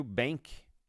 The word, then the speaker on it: BVNK: e o Bank, que se escreve BVNK, que é uma plataforma de pagamentos que faz essa fronteira entre os sistemas tradicionais e o mundo cripto.